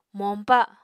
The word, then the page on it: moñpa: Apatani